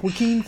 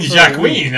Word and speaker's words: Joaquin: After like almost 50 episodes of this, like I used to be able to stay the course and actually continue the right pronunciation, but Mike rubs off on me, and if I hear him say it like wrong two times, like Joaquin Phoenix last episode, like I couldn't say. – Oh, you mean Jacqueline? Of course. – Yeah, Joaquin, Jacqueline.